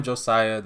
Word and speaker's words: Joxcya: I am Josiah, that's J-O-X-C-Y-A, pronounced just like the regular Josiah, which I am also.